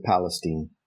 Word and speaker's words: Palestine: Is it Palestine or Palestine? – I asked her, and she said everybody there pronounces it Palestine.